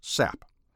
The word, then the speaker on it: SAP: It is passed to the listener as an audio signal on something conceptually called the Second Audio Program Channel, which is abbreviated SAP, and sometimes pronounced SAP.